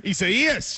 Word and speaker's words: Isaias: You were trying to get away from, how do we say it again? – Isaias. – Isaias, trying to get away from it.